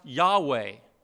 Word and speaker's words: Yahweh: And so as people over the years have tried to figure out how this name is pronounced, you know, most scholars think it was pronounced Yahweh, Y-H-W-H, the four consonants, Yahweh.